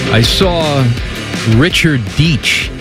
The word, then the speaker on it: Deitch: He's Swiss. No, he's Deitch. – He's Deitch. – Yeah. And I say it like Spice.